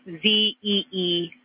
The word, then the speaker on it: Zeeman: Okay, and can you say the first letter? I may be pronouncing that wrong. What's the first letter of that word? – Zulu. It's Zeeman.